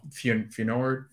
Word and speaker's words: Feyenoord: No, Feyenoord. – Feyenoord? – Thank you. Yes, thank you.